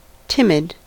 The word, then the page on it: timid: American English